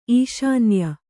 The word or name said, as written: ಈಶಾನ್ಯ